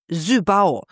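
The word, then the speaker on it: SYBAU: Renate 21 schreibt, meine Tochter hat mich aufgeklärt, Seebau spricht man Sübau. Die Betonung auf Au.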